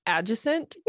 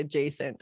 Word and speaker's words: adjacent: The word for me is adjacent. – It's kind of like fagitude. – Yeah, it's just that I emphasize the wrong part of the word. It's really adjacent, apparently.